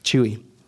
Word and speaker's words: Chuy: A thing that happened just today that's kind of interesting is my name, Chuy. C-H-U-Y, pronounced Chuy, but if you say Chuy.